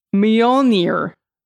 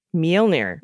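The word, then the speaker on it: Mjölnir: The best I can render it without the international phonetic alphabet is Mjolnir. – Mjolnir. – Mjolnir. – Mjolnir. Mjolnir, she wrote.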